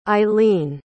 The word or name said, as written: eilene